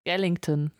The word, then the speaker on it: Skellington: In Nightmare Before Christmas geht es um Jack Skeleton und äh, Skellington. Skellington. Skellington. Ich habe seinen Nachnamen nicht ganz korrekt ausgesprochen.